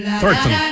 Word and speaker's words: Thornton: Thornton.